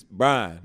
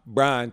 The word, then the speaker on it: Bryan: But in Bryan, Texas, it's just one syllable. It's Bryan. – Bryan.